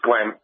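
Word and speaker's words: Glen: My name is Glenn, four letters, and it's Glenn. Mm-hmm. Now, that's my name properly pronounced. Now, right. – I've worked with kids who can render my name as Gan. Now, if I hear them calling out to me in that word, that name, I respond to them because I know they're not capable of pronouncing my name correctly.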